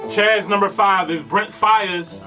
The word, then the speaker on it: Brent Faiyaz: Brent Faiyaz.